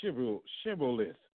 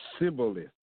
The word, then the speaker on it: Shibboleth: Say this, say Shibboleth, and he would say Sibboleth. So he left the H out, for he could not pronounce it right.